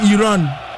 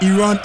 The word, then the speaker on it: Iran: He pronounced Iran as Iran. The Iranian journalist was not having it. He told him to pronounce it well and pronounce it Iran instead of Iran.